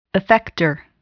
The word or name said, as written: effector